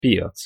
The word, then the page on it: piac: Hungarian